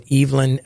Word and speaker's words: Evelyn: Normally, you might think, you know, Evelyn is the pronunciation of the name, but Evelyn with Eve on the accent on the Eve has a, you know, I think a particular significance, if you want to think about it, as Eve.